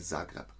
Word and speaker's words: Zagreb: Branka 123 sagt, Zagreb spricht man aus wie Zagreb.